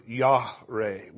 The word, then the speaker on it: yare: The word there is the word Yahreh, which is spelled Y-A-R-E.